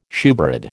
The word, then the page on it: shewbread: American English